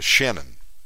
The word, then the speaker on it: Shannon: You know, my name is Shannon, but some people in Colombia would call me Channon, Channon, Channon, and uh, I know I know who they're talking to.